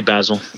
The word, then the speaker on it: Basel: Meanwhile, I mean, not for nothing, I went over to Art Basel, Basel. How do you pronounce it? – I say Basil. – Basil? Okay. I say Basil because I'm Italian. So yeah, a lot of people say Basil.